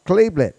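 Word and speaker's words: Chalybeate: The first 3 years of my life, I lived in a town called Cleblett. And somebody says, how do you spell that? Well, it's C-H-A-L-Y-B-E-A-T-E, an Indian name.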